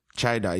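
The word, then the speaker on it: chiydah: Chida is spelled C-H-I-Y-D-A-H. C-H-I-Y-D-A-H.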